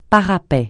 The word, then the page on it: parapet: francais